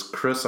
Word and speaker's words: Chrys: So the one cool thing that obviously your fans already know is that your artist stage name, Chelsea, is Chrys. I believe it's spelled C-H-R-Y-S, like a short version of the word chrysalis.